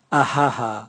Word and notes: aha aha: Raga

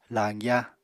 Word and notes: lañgya: apatani

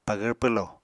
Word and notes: pagvr pwlo: Apatani

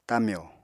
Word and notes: tamyo: apatani

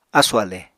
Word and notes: ásù-ale: Apatani

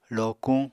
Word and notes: lokuñ: Apatani

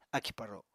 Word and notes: akì-paro: apatani

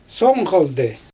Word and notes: som xaldɯj: Xyzyl

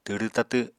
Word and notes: twrw tatw: apatani

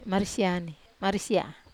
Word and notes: marisia: Wamesa

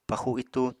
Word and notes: paxu itú: Apatani

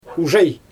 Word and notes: uʒaj: Xyzyl

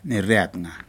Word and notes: niri atga: Aneityum

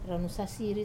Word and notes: ranu sasiri: wamesa

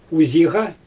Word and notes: uziiɣa: Xyzyl

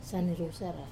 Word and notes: sane rusara: wamesa

